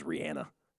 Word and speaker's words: Rihanna: She says the proper pronunciation is Rihanna. But no one calls her that. Yeah, it's Rihanna.